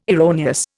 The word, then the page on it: erroneous: Indian